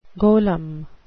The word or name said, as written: golem